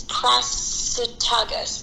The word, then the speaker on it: Prasutagus: It's Prasutagus. And she had two children with them, although they're not listed in a lot of the kind of works that you can find on her. But Prasutagus was a Roman sympathizing chieftain.